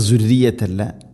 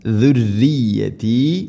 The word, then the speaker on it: ذريتي: ദുർറിയത്തി ദാലിൻ്റെ ഉച്ചാരണം ശ്രദ്ധിക്കുക, സുരിയത്തല്ല ദുർറിയത്തി.